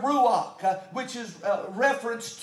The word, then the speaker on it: ruach: That word Spirit in the Hebrew, to be able to pronounce Hebrew the way it was intended, I've often heard that you have to talk like you have a hairball stuck in the back of your throat to really get close to the Hebrew pronunciation. Well, I don't have that. But the Hebrew or the redneck version of this word is the word ruach.